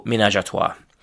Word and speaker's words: ménage à trois: So, the next word in this category is ménage à trois, and it's generally pronounced in English with something like the French pronunciation, so ménage à trois.